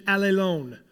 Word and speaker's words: allelon: I found it amusing that the pronunciation of the Greek word is so similar to our words, all alone. Did you hear it? The Greek word, the pronunciation, allelon.